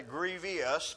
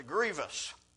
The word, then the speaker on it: grievous: I sometimes mispronounce that and say grievous, but that's not the word that occurs. It's grievous.